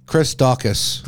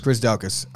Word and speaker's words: Daukaus: Curtis Blaydes enters the heavyweight title mix with a second round convincing win over Chris Daukaus. – Chris Daukaus.